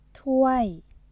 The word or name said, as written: ถ้วย